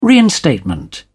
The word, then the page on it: reinstatement: British English